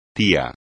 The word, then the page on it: tía: espagnol